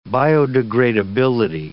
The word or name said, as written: biodegradability